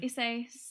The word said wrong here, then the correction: Issei: Issei Sagawa.